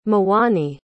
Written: mwani

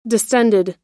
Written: distended

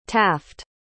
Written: taft

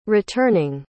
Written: returning

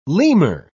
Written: lemur

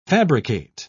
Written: fabricate

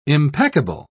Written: impeccable

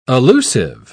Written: allusive

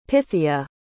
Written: pythia

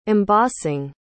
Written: embossing

